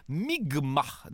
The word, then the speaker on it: Mi'kmaq: où Jacques Cartier et son équipage vont être accueillis par des membres de la nation Micmac, euh, qui se prononce en fait Mi'kmaq, dans ce qui sera le premier contact.